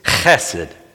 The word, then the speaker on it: chesed: would pronounce it in the Hebrew way, which is chesed. Chesed. It's spelled H-E-S-E-D, and it's pronounced with the ch at the front.